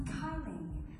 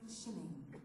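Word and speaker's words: kylling: can we please have some Kyling? Because in the States we pronounce K-Y as Kai. And the lady at the counter was like, you mean shilling?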